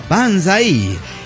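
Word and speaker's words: pensaer: Se pronuncia Panzaí, ya que yo soy arquitecta y un poco cursi, pues decidí renombrarme Panzaerita.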